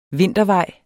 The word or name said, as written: vintervej